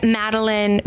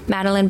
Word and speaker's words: Madeleine: Yeah, Madeleine Bundy, and it's Madeleine spelled like the cookie. – Oh yeah. – E-L-E-I-N-E. Madeleine Bundy.